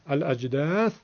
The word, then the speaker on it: الأجداث: بله، الأجداث در زمان وقف حرف فا در آخر هم ساکن شد و به این نحو تلفظ شد.